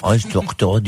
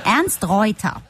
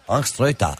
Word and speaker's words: Ernst Reuter: Ernst Reuter deyince. – Nein, Ernst Reuter. – Ernst Reuter, evet.